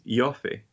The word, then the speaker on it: Ioffe: And so, but now, no, I guess the reason this article exists is because it's by Julia Ioffe, or how do you say her horrible name, the Russiagate truther lady? – I think it's Ioffe, yeah.